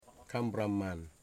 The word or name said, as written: Kambraman